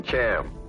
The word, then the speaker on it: Chaim: Dr. Weizmann's first name was C-H-A-I-M. Now, I didn't know how to pronounce it, so I called him Cham.